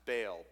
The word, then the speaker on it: Baal: which is Baal, or you may have heard it pronounced Baal, B A A L.